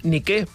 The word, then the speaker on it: Nike: La marca de roba esportiva no és Nike ni Nike, és Nike, perquè ve del grec. Per tant, la pronúncia adequada seria Nike.